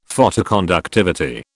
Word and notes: Photoconductivity: British English

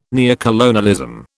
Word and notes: neocolonialism: British English

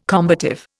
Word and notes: combative: Indian

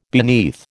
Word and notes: beneath: American English

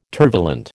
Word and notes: tervalent: American English

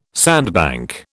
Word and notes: sandbank: British English

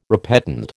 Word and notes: repetend: American English

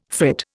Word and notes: frit: Indian

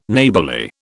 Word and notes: Neighbourly: British English

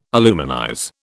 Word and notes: aluminize: British English

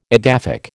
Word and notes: edaphic: American English

